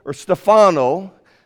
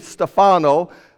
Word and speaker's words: Stefano: A video discussion with Stefano. That's how you say it in Italy. Stefano. I knew him as Stefano.